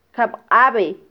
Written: kabqabe

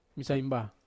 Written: masaimba

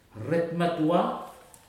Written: retu matua

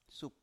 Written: sup

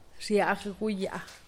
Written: xiagguyaa

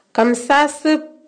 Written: Kamsasip

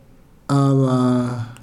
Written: aba

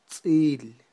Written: tz'il

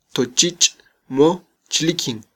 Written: tochich

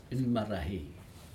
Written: inmerahi

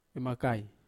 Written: vemakai

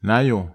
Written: naio

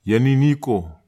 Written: ieniniko

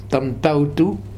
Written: Tamtautu

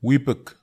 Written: wipek

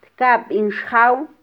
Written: tkabin xjaw

